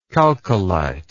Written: chalcolite

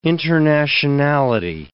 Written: internationality

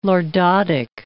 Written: lordotic

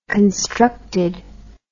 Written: constructed

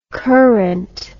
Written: courant

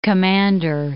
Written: Commander Islands

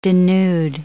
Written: denude

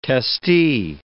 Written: testee

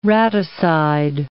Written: raticide

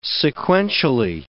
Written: sequentially

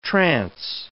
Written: trance

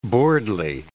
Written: buirdly

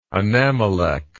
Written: anammelech

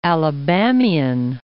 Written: Alabamian

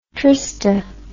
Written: trista

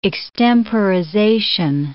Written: extemporization

